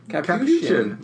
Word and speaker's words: Capuchin: It's all about the chin. – Capuchin. Yeah. No, it's about the shin.